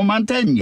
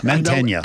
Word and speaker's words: Mantegna: Joe Mantegna. – Yeah, Joe Mantegna. – Mantegna.